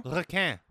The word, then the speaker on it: Requin: How do you pronounce? Requin? – Requin. – Requin. Okay, there you go. – Requin. It's French for shark.